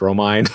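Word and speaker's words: Romine: I always say his name Romaine, but it's Romaine. I always want to say his name like it's lettuce.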